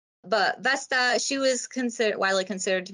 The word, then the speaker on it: Vesta: Vesta? – Vesta.